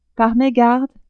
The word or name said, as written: par megarde